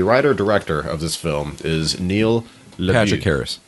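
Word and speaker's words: Neil LaBute: I believe his name is pronounced Neil LeBue. But it's like spelled like Neil the Butt.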